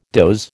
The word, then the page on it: doze: American English